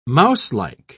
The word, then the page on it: mouselike: British English